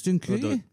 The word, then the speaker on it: dziękuję: Thank you is dzięki. – Dobrze is good. It takes drugs. – It's good. – I mean, Dobrze or Dobre is good. – Dobrze. – Dzięki is how you say it. – It's dziękuję. Dziękuję.